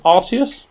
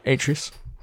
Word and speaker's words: Atreus: Kratos and Artius. – Atreus.